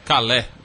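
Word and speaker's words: Calais: Desmonte do acampamento em Calá provoca protestos e polícia interfere. – Calé. O Chico já está ali condenando a sua pronúncia.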